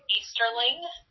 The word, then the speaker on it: Easterling: Uh, my name is Amy, A M Y, Easterling, E A S T E R L I N G.